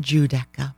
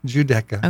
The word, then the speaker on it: Giudecca: But it's spelled G-U-I-D-E-C-A. – It's because Guido. – Right. – But sorry. – It's pronounced Giudecca. – Giudecca.